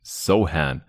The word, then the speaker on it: Sochan: Ich habe äh, in den Pots bisher immer Sochan gesagt, aber er hat es selber klargestellt ähm, in so einem Vorstellungsvideo, das ich neulich gesehen habe. Und dann nennen wir ihn natürlich auch so, wie er ausgesprochen wird.